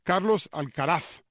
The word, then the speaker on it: Carlos Alcaraz: Carlos Alcaraz, lo que pasa es que tuve que ver a Efraín para ver si lo estaba pronunciando correctamente. Carlos Alcaraz, ¿verdad? ¿Está bien? Ok.